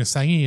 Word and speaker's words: Assange: But apparently she slags off Julian Assange in the interview. – Mm. – By the way, this is how everyone should pronounce his name.